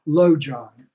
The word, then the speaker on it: Lojong: So let's look at Tibetan practices that are called Lojong. Lo means mind, and Jon has a couple of meanings. It's spelled L-O, transliterated, it's spelled L-O-J-O-N-G, Lojong.